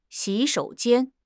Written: xi3 shou3 jian1

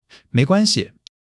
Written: 没关系

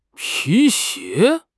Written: pi2 xie2

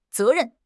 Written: ze2 ren4

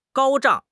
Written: 高涨

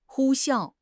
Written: 呼啸

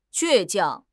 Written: 倔强

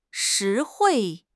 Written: 实惠

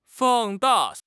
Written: 放大

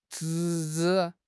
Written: 侄子

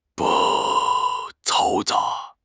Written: 嘈杂

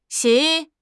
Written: xi3

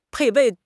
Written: 配备

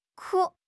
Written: ku1

